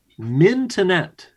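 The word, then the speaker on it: Mintonette: What sport was originally going to be called mintonet, as in the first syllable is mint?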